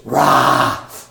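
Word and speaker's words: rah (רע): It's just rah is how you say it. Rah, it's a deep guttural.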